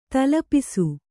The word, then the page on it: ತಲೆಪೂಸು: Kannada